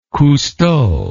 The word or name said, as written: Cousteau